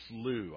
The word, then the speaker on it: Slough: I've actually asked my Scottish theologian friends, how do you pronounce the Slough of Despond? It's Slough.